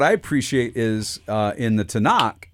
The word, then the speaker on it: Tanakh: Tanakh.